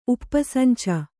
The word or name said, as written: ಉಪ್ಪರಸಂಚ